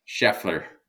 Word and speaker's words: Schaeffler: Desktop Metal announced that they had divested of Aerosint to Schaeffler, am I saying that right when I say Schaeffler? – Schaeffler.